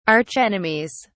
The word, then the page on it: archenemies: British English